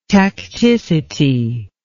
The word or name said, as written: tacticity